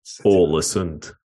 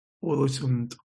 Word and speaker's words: Ålesund: Can we start at the end in your pronunciation of Ålesund? Alisson. It's not a Brazilian goalkeeper. It's a city by the fjord, you know. Ålesund. – Ålesund.